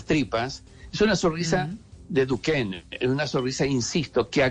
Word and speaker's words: Duchenne: Hay un estudio muy importante que se ha hecho que se llama La sonrisa de Duquesne Duchenne, Duquen, la pronunciación,